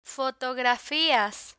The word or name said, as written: fotografias